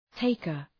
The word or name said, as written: taker